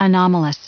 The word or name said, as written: anomalous